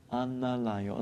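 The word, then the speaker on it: Anālayo: And the Analayo, A-N-A, or it's the second one, it's a long A, L-A-Y-O, Analayo.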